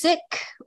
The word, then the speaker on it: Sikh: So in Punjabi, it's Sikh with an aspirated H. But the faith, the community has been called Sikh for many years as well.